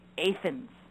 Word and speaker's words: Athens: It's spelled like Athens, Greece, but it's pronounced, thank you very much, Athens.